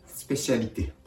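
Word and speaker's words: spécialité: スペシャリテ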